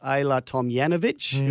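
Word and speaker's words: Ajla Tomljanović: Ayla Tomjanovic, who we've been told that is how you pronounce her name.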